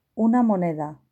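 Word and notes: una moneda: Spanish (Spain)

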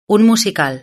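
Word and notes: un musical: Spanish (Spain)

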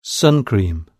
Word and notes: sun cream: British English

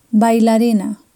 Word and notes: bailarina: Spain

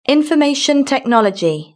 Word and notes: it information technology: British English

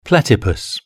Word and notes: platypus: British English

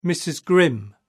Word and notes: mrs grim: British English

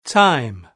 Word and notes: time: British English

